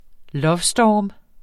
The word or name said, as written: lovestorm